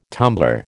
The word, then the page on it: tumbler: American English